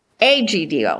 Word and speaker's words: Egidio: Egidio. – Because it starts with an E, but it sounds like an A.